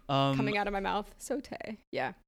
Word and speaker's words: sauté: And then one dancer is doing like a sauté arabesque in the air. Did I say that right? – Yeah, sauté.